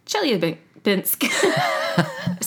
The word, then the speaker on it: Chelyabinsk: Chelyabinsk? – Chelyabinsk.